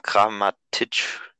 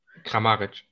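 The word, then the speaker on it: Kramarić: Hoffenheim konnte noch mal ein bisschen bessere Plätze in der Europa League kriegen und die vier Tore von Kramatic, ist es richtig ausgesprochen? – Kramaric.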